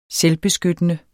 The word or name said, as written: selvbeskyttende